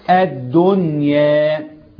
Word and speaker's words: الدنيا: مش هنعرف دي كلمه ايه، وهذا يسمونه اظهارا مطلقا، فلا تنطق الدنيا في القران الا الدنيا، نظهر النون، وده اسمه اظهار ايه؟